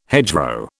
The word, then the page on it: hedgerow: British English